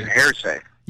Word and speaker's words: hearsay: Yes, but I say heresy. – Well, hearsay. Yeah, that's a legal term. – Yeah, but I say heresy. – You were pronouncing it like heresy. Hearsay.